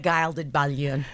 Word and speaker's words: Gilded Balloon: The Gilded Balloon. Is that not how it's pronounced? – No, I think you're the first one to nail it, actually. Everyone's been traipsing around saying it wrong.